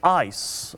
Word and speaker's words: eis: We would say it in English, we would spell it E-I-S, and we would pronounce it as ice, okay?